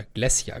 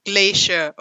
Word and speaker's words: Glasya: Steinlawinen sind häufig und die Erzfürstin hier, die aktuelle, ist Glacia, oder Glacia, glaube ich, ist die richtige Aussprache, Asmodeus Tochter. – Also aber nicht, nicht wirklich Glacier, oder? nee, Glacier. Also man buchstabiert sie G-L-A-S-Y-A.